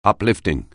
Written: uplifting

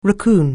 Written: raccoon

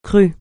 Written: crue

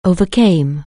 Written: overcome

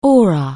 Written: aura

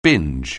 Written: binge

